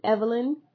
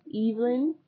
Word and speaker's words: Evelyn: or I guess Americans pronounce it Evelyn, but when we hear it on Coronation Street, it's Evelyn.